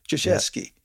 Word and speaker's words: Krzyzewski: I think that's just how you pronounce his name. Duke University's head coach, Krzyzewski. That's correct. Thank you for helping me with that name. It's a difficult name to pronounce.